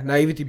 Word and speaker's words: naivety: Am I saying that correctly? – Yeah. – Yeah, naivety.